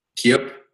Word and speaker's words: KeyUp: Another project that you're involved in is, I don't know if I'm saying this right, but the KeyUp. – KeyUp, yeah. – KeyUp. Describe what this is. Who is this actually targeted as at? – So the name is actually said as KeyUp, and in Bulgarian, it basically means a container.